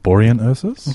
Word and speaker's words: Borean Ursus: Now, I'd like to shout out to, I'm going to try to pronounce this, Baron Ursus. – I'm pretty sure it's Borean Ursus.